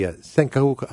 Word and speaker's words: Senkaku: Over a set of, they have these, the set of uninhabited islands called the Senkaku, I think it's the Senkuka, S-E-N-K-A-K-U, I think is how it's spelled.